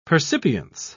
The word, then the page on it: percipience: British English